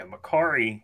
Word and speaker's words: Mercari: Yeah, Mercari is another site, kind of like eBay, and they sell other stuff.